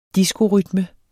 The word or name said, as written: diskorytme